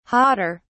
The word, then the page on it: hotter: British English